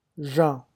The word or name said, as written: Jean